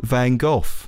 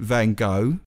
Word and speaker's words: Van Gogh: Now, we know Americans pronounce it Van Gogh. British people pronounce it Van Gogh. The way that the BBC suggest their announcers pronounce it is Van Gogh or Van Gogh.